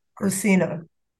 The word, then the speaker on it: Cursino: Cursino.